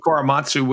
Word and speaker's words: Korematsu: Korematsu, is that my pronouncing that? – I mentioned Korematsu, which was never actually overturned.